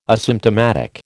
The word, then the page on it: asymptomatic: American English